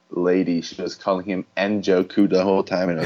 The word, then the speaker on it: Njoku: Hilton and Odell Beckham Jr. as the receivers, David Njoku at tight end. Is that a silent N? I'm not sure. Anyways, Chargers defense and Kaimi Fairbairn as the kicker. – No, I believe everyone calls him Njoku,